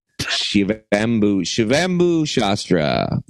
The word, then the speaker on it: Shivambu Shastra: In a traditional practice known as Shivambambu Shastra. Shivambu Shastra. I said it right.